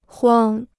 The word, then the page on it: 光: guāng